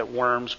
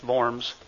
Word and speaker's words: Worms: It's spelled like worms. I think that's the way we sometimes say worms, but in German it's Worms.